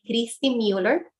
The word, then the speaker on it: Christy Mueller: Puedes buscarme también ahí, Christy Mueller. Y estos son los dos libros. Se llama Una vida mejor. Mi nombre se escribe con CH, Y al final y como Muller, para los que me van a seguir en las redes sociales.